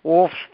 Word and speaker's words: Wulf's: I'd never heard of it though, but tell us the fit, the wolf, spelled with a U, by the way, listeners, WULF'S, wolf's fish.